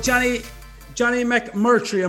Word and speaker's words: Jonny McMurtry: All right, so Jonny McMurtry, am I saying that right? – You've nailed it, 100%.